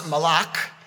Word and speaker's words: malach: In the biblical Hebrew, angel is pronounced malach.